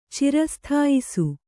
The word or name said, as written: ಚಿರಸ್ಥಾಯಿಸು